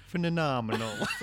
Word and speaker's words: phenomenal: Andy Dufresne was phenomenal. I think you're putting an extra syllable in there. Phenomenal.